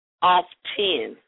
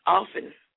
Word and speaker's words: often: Off 10, like off, and then 10, like the number 10. Correct pronunciation is often. like off, the T is silent.